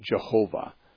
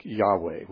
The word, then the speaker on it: Yahweh: And they stretch them up, adding vowels to them, and pronounce it Jehovah with a J sound at the beginning. More commonly, Hebrew scholars would, I think, endorse a pronunciation more like Yahweh, which is, you hear that much more often now by those who are trying to be authentic.